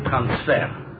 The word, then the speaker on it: transfer: You see, an Israeli calls it transfer. All right. Because they put the accent on the last syllable, regardless of what language it is. So transfer. By us, it's transfer.